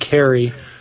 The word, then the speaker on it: Kahre: The gentleman's last name is spelled K-A-H-R-E, but I believe he pronounces it Carey.